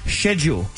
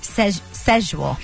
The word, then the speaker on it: schedule: The correct way to say it is schedule. – No, it's not. – Yeah, it is. – It's schedule.